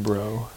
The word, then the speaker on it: Breaux: What do you mean, bro? – B-R-E-A-U-X. Bro. That's how you say it.